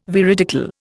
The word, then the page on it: veridical: Indian